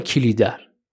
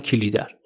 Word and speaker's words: کلیدر: خود جناب دولت آبادی توی مصاحبه هاشون که من گوش کردم میگن کلیدر و لذا منم میگم کلیدر